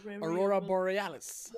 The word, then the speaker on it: Aurora Borealis: Aurora Borealis.